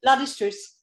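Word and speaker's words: La Destrousse: How do you pronounce where you live? – Not in French. La Destruce. – La Destruce. Okay.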